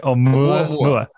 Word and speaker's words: Oumuamua: Oumuamua.